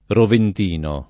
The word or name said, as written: roventino